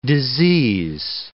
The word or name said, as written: disease